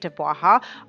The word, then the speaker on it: Dubwaha: Now, if you're curious, that is how you say it, Dubwaha.